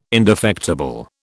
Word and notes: indefectible: British English

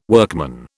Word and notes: workman: British English

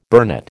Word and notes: burnet: American English

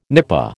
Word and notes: nipa: American English

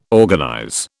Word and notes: organize: British English

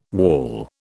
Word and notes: waul: British English

